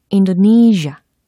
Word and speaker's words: Indonesia: I-N-D-O-N-E-S-I-A 라는 철자입니다. 인도네시아 발음이 어떻게 될까요? 오, 인도네시아, 인도네시아. Indonesia. 요렇게 해주시면 되겠습니다. 오, 신기한 게 끝부분에 있는 S라는 철자의 발음은 마치, 어, 인도네시아, 인도네시아 라고 들렸잖아요. 그래서, 젬, 젬, 젬. 이렇게 사운드 처리를 해주시면 되겠습니다.